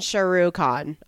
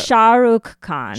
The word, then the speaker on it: Shah Rukh Khan: Then Shah Rukh Khan, I think.